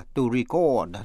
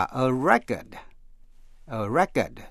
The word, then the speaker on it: record: Cũng phân biệt động từ to record, R-E-C-O-R-D, động từ đọc là to record, là ghi lại. Và a record, danh từ, thì nhấn mạnh phần đầu, a record là bản ghi lại, hay là thành tích, hay là kỷ lục, cũng có nghĩa là đĩa hát.